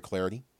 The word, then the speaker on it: Clardy: Also, give me the follow, at Troy Clarity. At Troy Clarity, last name is spelled C-L-A-R-D-Y.